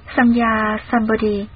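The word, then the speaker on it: Samyak Sambodhi: Chữ Tam Miệu Tam Bồ Đề, nguyên âm Ấn Độ đọc là Samya Sambodhi, dịch ra chữ Hán là chính đẳng chính giác, nghĩa là giác ngộ bình đẳng, chân chính hoàn toàn.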